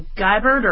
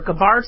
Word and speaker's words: Gabbard: And so you have Cory Booker, Tulsi Gabbard, or Gabbard, sorry, Kristen Gillibrand, Kamala Harris, Amy pronounce the names, and then Elizabeth Warren.